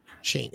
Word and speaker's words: Schoen: How do you say it? – Shane.